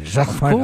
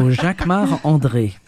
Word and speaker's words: Jacquemart-André: You say it like André. – A Frenchman. – Jacquemart-André. Of course, because I'm French. So Jacquemart-André.